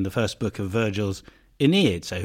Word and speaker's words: Aeneid: Okay, well, first of all, let's pronounce it right. In English, it's the Aeneid.